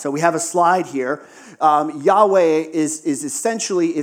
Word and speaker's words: Yahweh: But yes, as best we can guess, that is pronounced in English, Yahweh. Yahweh.